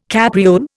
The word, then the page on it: capriole: Indian